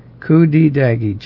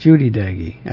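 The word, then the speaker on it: Cudi Dağı: Kudidagi, Judidagi. Actually, it's pronounced Judy in Turkish, Judidagi.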